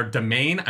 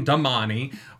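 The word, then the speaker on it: damane: They are Domain. I'm not saying Damani.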